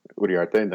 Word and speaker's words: Uriarte: You know, and my last name was too hard to pronounce in the Marine Corps, Uriarte.